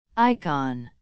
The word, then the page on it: icon: American English